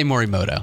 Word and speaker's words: Morimoto: We're going to say Morimoto.